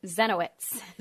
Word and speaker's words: Zieniewicz: Zenowitz.